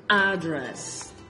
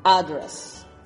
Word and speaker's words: Address: And the number one is a. Address. Number one. Number two is e. Italy. Da la coincidencia que en esos dos primeros, justo la primera sílaba es la primera que se es stress.